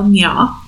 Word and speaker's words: Nga: N-G-A is how you spell Nya or Na.